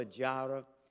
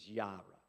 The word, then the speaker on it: Yireh: Now, I know typically you hear it uh, Jehovah Jireh. The J in Hebrew is pronounced as a Y. So, really, the most correct pronunciation is Yireh.